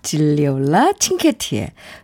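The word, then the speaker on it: Gigliola Cinquetti: 진리올라 칭케티의 논호레타. 이 발음하기가 어려워서 그런 제가 좀 헤매는데요. 질리올라 칭케티의 논호레타 듣고 오겠습니다. 아, 네. 질리올라 칭케티의 노노레타.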